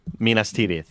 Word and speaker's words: Minas Tirith: And he goes, Minas Tirith. Did I say it right? – Minas Tirith. – Minas Tirith. – Yeah.